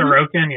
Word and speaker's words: Sorokin: Um, no, there's no book on on uh, how do you say it, Sorokin? – Mm-hmm. Sorokin, yeah. Sorokin.